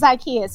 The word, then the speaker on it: Zakiya: It's Zakiya.